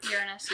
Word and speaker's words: Uranus: Uranus is the correct pronunciation.